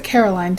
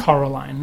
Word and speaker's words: Coraline: So next week we're going to be having the movie Coraline. I think that's how you say it, on Blu-ray disc. – Looks like Caroline to me. – No, it's got an O. – Yeah. – Coraline, not Caroline.